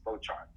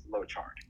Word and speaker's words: Lochard: Just Lochard. – Lochard. Lochard. Lochard. – It's like what I tell people is like, just imagine. My name is spelled L-O-W, but no W. Like low chart, just no W.